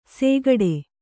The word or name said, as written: ಸೇಗಡೆ